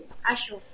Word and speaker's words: Azure: Azure no sé si se pronuncia bien Azure no me parece que sí Azure ajá Azure ah,